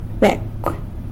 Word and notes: becw: sanlucasquiavini

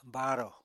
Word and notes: baarò: apatani

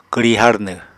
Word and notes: kwri harnw: Apatani

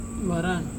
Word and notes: ivaran: Serewen

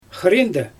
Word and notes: xɯriindɛ: Xyzyl

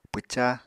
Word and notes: pwca: apatani

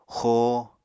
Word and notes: xoo: Apatani

